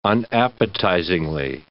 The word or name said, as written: unappetizingly